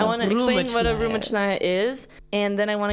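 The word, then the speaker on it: Rumichnaya (рюмочная): Meaning we only went to this specific type of establishment called a Rumichnaya. I'm saying it like completely American, but it's okay. – How do you say it in Russian? – Rumichnaya.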